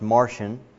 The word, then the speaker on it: Marcion: Then there's another, on the other extreme, a fellow by the name of Marcion, M-A-R-C-I-O-N, and I think I have a picture of him here, and otherwise sometimes pronounced Martian.